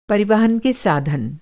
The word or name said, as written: परिवहन